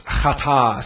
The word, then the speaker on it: chata': The first verb is chata, C-H-A-T-A, apostrophe. Chata. And that apostrophe is very important, by the way. It represents the Hebrew letter Aleph. It is not pronounced necessarily, but it is a closing of the throat. It is like in the English language when you say the word honest. The word honest starts with an H, but we don't say honest.